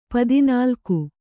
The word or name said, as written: ಪದಿನಾಲ್ಕು